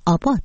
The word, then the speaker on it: ABBA: بشنوید که هواداران گروه سوئدی افسانه‌ای موسیقی پاپ ابا که در ایران آبا تلفظ میشه، 50مین سالگرد برنده شدن این گروه رو در یوروویژن 1974 میلادی جشن میگیره.